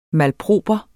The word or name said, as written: malproper